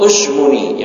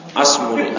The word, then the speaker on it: Ashmuni: Kalau Ashmuni yang benar, bencara Alfiyah Ashmuni, bukan Asmuni. Asmuni pelawak.